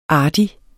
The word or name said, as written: artig